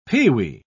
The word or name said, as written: peewee